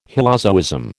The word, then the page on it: hylozoism: American English